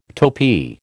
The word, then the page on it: topee: American English